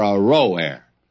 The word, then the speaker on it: Aroer: Now, I probably ought to go back and give you another pronunciation of that. The cities of Aroer, are forsaken. And let's settle for Aroer.